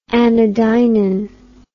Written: anodynin

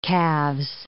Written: calves

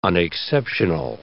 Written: unexceptional

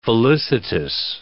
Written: felicitous

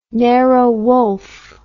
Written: Nero Wolfe

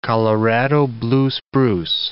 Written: Colorado blue spruce

